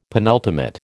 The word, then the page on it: penultimate: American English